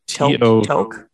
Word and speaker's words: toque: T-O-Q-U-E. – Toque? – I think that's Canadian.